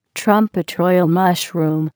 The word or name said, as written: trumpet royale mushroom